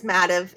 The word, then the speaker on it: Mativ: But it is Mativ. It had an E on it at first. We took the E off and we thought that would help with the pronunciation, but I think we were kidding ourselves because there was still some confusion on it.